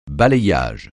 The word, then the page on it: balayage: francais